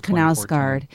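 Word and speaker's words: Knausgård: It is Knausgard.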